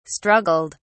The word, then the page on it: struggled: British English